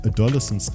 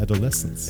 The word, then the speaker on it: Adolescence: Ja, weil ich es ganz oft im Netz von deutschen Leuten falsch ausgesprochen gehört habe, die haben Adolescence gesagt, aber man sagt Adolescence.